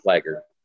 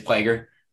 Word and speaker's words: Plager: I don't know how to say his last name. – Plager. – It is Plager.